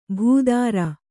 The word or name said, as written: ಭೂಧ್ರ